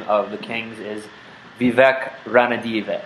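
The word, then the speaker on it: Ranadivé: It's got one of those things on the E at the end, one of those little tilde things. Ranadive.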